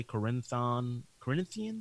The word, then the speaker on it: Corinthian: Corinthian? – Yeah.